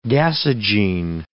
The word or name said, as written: gasogene